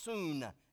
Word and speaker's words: syn: S-Y-N in the Greek, by the way, is pronounced soon, S-O-O-N.